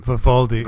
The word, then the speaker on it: Vivaldi: You can install the Pepperflash on Vivaldi. I keep pronouncing it differently every time. – Vivaldi.